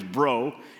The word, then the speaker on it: Breaux: Now, my, my last name is Breaux, and it's spelled B R E A U X.